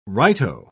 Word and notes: righto: British English

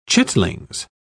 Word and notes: chitlings: British English

